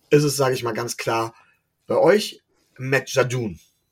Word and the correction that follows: Judon: Oder Judon, Judon heißt er.